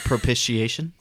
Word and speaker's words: propitiation: Propitiation.